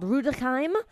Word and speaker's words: Rüdesheim: I lived in a small town called Rüdesheim, and did you like how I pronounced that so well? I had the It sounds perfect.